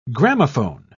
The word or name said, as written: gramophone